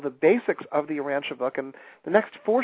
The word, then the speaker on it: Urantia: It's U-R-A-N-T-I-A. That's U-R-A-N-T-I-A, Urantia.